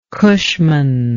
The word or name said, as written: cushman